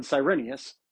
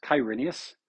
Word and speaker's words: Cyrenius: It also happened when Cyrenius, sometimes pronounced Kyrenius with a Q, was governor of Syria.